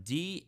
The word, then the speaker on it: Daszak: This is an email from who I told you, Dr. Peter Daszak. I couldn't pronounce his name the first time. Daszak, president of EcoHealth Alliance.